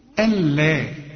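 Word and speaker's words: ألا: اقول لك برضو قاعده هنا، في نون عندك اهي، اسقط النون دي من النطق وكانها غير موجوده، طالما ان بعدها لام، تلاقي نون ساكنه بدون تشكيل بعدها لام، الغيها من النطق وتقول الا.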